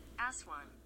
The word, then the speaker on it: Aswan: Aswan? – Yeah. – Aswan. You said it correctly. It sounds like a double S word.